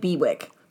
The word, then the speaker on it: Bewick: I like this little wren, although just as a note for spelling, it's spelled B-E-W-I-C-K, so it looks like Bewick.